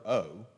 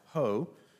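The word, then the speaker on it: ho (ὁ): It's just the letter O. That's what it is. We might say O. It's actually pronounced ho.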